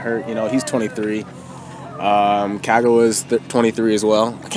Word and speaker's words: Kagawa: I've always been saying Kagawa, but I looked at the pronunciation. It's Kagawa.